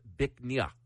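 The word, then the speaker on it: Bích Ngà: It's spelled B-I-C-H-N-G-A. It's pronounced Bichnia, which loosely translates to beautiful jade. – Oh, Bichnia.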